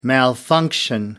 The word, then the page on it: malfunction: American English